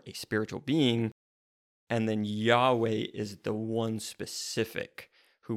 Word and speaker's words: Yahweh: But the Lord, in all caps, is the actual name of the God of the Bible of Israel, and it's Yahweh. That's how we pronounce it. It's YHWH or YHVH, depending on how you look at the Hebrew there, and we fill in the vowels within that.